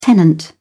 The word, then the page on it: tenant: British English